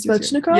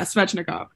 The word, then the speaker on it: Svechnikov: I don't want to mispronounce his name. I was Svechnikov. – Yeah, Svechnikov.